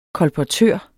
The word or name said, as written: kolportør